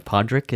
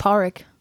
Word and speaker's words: Pádraic: How do you say uh, Podrick? Is that what it's it is? Pádraic. Pádraic. Yeah, Pádraic. – Yeah, Pádraic. I kept thinking it was Podrick, like the kid from yeah.